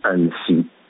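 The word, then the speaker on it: Annecy: اینا وقتی میرن، چون اصلا هیچ زبان خارجی بلد نبودن، تقریبا الان ساعت باید باشه طرف های 5:30-6، اینا تلفظ بد میکنن به جای اینکه بگن بلیط میخواییم برای شهر انسی، این انسی رو بد تلفظ میکنن. اینا دو تا میخرم بلیط میخرم برای شهر نانسی.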